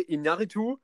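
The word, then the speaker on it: Iñárritu: Inaritu.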